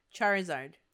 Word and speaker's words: Charizard: Charizard. – There we go. – There, you did it. You said it right.